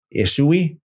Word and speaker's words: Ishvi: Again, the V can be pronounced as a U, Ishui.